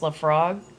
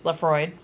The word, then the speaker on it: Laphroaig: And then the last one we tried, it looks like Laphroaig, but it's actually pronounced Laphroaig. I'll spell it out. It's Laphroaig. So again, that's pronounced Laphroaig.